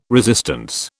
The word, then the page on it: resistance: British English